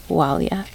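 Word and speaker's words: Máille: Even though it's not pronounced with an M, it's pronounced kind of with an oo sound, Walia.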